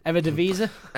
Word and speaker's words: Eredivisie: He's currently playing for ADO Den Haag in the Dutch Eredivisie. – Excellent pronunciation.